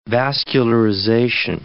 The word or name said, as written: vascularization